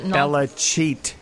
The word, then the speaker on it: Belichick: Belichick is how you pronounce that name.